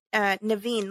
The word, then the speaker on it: Navene: His name is Naveen. Let me tell you how that's spelled. N-A-V-E-N-E, just like you would think.